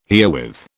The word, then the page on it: herewith: British English